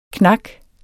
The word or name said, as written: knak